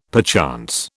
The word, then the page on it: perchance: British English